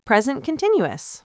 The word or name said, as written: present continuous